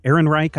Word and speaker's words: Ehrenreich: Ehrenreich, I think is how you say his name.